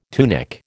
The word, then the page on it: tunic: American English